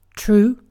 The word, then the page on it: true: British English